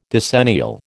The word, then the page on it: decennial: American English